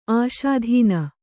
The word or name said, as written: ಆಸ್ಥಾನ